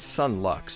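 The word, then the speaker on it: Son Lux: I mistakenly pronounced them Sun Lux, and it's a long story why I pronounced it that way. It doesn't matter. I was wrong. It's Sun Lux, so their nomination was 100% deserved.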